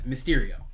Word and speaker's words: Mysterio: But funny about my name is I don't care how people pronounce it. I think it's funny. It's kind of campy to have it be pronounced two ways. – Uh-huh. – Certain people like to say it one way, other people like to say it the other way. I introduce myself as Mysterio.